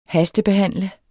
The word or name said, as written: hastebehandle